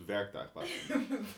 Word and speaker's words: werktuigbouwkunde: Ik ga werkbouwtuigkunde doen. – Je kan het nog steeds niet normaal uitspreken. Het is werktuigbouwkunde.